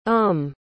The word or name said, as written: umm